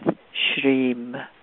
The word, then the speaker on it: Shrim: Now allow the breath to flow in with Shrim, Shrim, S-H-R, long I-M, Shrim, Shrim, Shrim, flowing in with the inhalation, dropping into the heart.